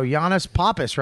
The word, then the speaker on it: Yannis Pappas: So Yannis Pappas, right? – Yeah.